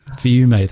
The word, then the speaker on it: Fiume: Fiume.